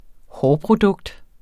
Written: hårprodukt

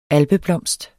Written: alpeblomst

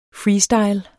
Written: freestyle

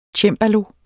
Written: cembalo

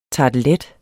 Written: tartelet

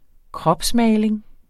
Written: kropsmaling

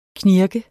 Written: knirk

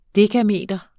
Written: dekameter

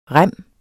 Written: rem

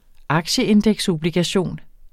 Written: aktieindeksobligation

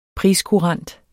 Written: priskurant

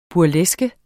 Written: burleske

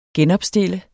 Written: genopstille

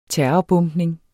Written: terrorbombning

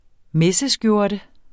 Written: MESSESKJORTEN

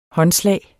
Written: håndslag

